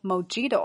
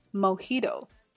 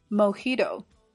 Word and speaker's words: mojito: 好，最后也是我们今天的重点词 mojito，这个词来源于西班牙语，在西语当中字母 j 发。的音，所以这款鸡尾酒不读作 mojito 啊，而是 mojito。 mojito。好，那么最后再来补充鸡尾酒，英文叫做 cocktail。 cocktail。 OK，我们把整句连起来。 Hey, let's try one of their famous mojitos.